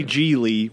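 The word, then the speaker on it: Gigli: Larry G. Lee rhymes with really, as is said in the film.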